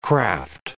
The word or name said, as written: craft